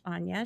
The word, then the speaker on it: Aine: And that includes Anya, which is spelled A-I-N-E, but it's pronounced Anya.